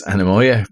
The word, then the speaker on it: Anemoia: And it's how you pronounce it is Anamoya, but how you spell it, A-N-E-M-O-I-A. Yes, that's how you spell it. And then it's on Instagram, spelled another way, A-N-E-M-O-Y-E-A-H.